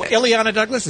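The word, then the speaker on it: Illeana Douglas: Oh, Ileana Douglas? Is that who you're talking about? – Oh, yeah. Ileana Douglas. Sorry. – Yeah, she's a good actress. – I mispronounced that name.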